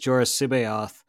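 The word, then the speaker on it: Jorus C'baoth: This was in the Legends canon, this is the facility where like Jorah Sibayoth, which is Tim Zahn's pronunciation of that, I asked him,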